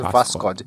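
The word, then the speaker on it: Vascod (Восход): Mas a transliteração é, é com O, porque tem um O russo lá, mas é só a pronúncia, eu não tô aqui falando errado, é, o correto russo é Vascod.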